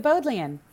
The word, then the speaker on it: Bodleian: And if you went to Oxford, I'm not sure I know how to pronounce it, but did you go to the Bodleian Library? – You said it right, the Bodleian.